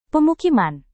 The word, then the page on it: pemukiman: Indonesian